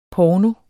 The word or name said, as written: porno